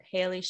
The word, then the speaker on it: Hayley: You can find me on LinkedIn at Hayley Schiller. For those of you who don't know, it's spelled H-A-Y-L-E-Y, last name S-C-H-I-L-L-E-R.